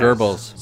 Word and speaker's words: Goebbels: Let's talk about, how do you pronounce his name? Goebbels, the propaganda guy from Nazis? – Goebbels. – Goebbels? – Yes. Goebbels.